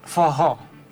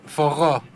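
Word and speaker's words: forró: ¿Me repite el nombre del género? – Fojó. – Fojó. Así sí. Está bien pronunciado.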